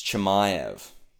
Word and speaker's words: Chimaev: You've heard me say Chimaev, Chimaev. It's Chimaev.